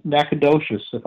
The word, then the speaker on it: Nacogdoches: And we went down to Windy Ridge in Nacogdoches. I hope I've said that right. – Yes, I think you did.